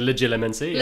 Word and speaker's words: legilimency: The fact that Voldemort could do this, that now that legilimency? Mm-hmm. How do you pronounce it? – Let's go with legilimency.